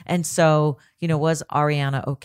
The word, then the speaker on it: Ariana: All right, so, before you go, it's Ariana Grande, not Ariana. I know her fans absolutely hate that. Yeah. When people say it wrong.